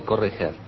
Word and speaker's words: Corretjer: El que habla es el gran poeta puertorriqueño Juan Antonio Corretjer. ¿Se pronuncia así exactamente? Corretjer. – Corretjer. – Corretjer. – En puertorriqueño. Los catalanes, mi familia lo dice de otra manera, pero yo no lo puedo imitar.